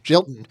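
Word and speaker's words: Gjelten: Although if you know the legislative history, how do you pronounce the guy's name? G-J-E-L-T-N? – Jilton. – Yeah. Right.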